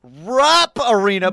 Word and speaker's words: Rupp Arena: I drafted Rupp Arena, but I cannot say the word.